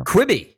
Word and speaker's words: Quibi: Quibi, yes. – Quibi, not Quibi, but Quibi. Like, don't yes. – quibble, it's pronounced Quibi. Okay. – Yeah, like quick bites. – That's right, quick bites, Quibi.